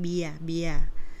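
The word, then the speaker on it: เบียร์: It's actually the same with English, but with different pronunciation, with like no R, just like beer.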